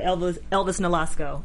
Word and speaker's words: Elvis Nolasco: I just want to say as a side note, we never talk about Elvis Nolasco.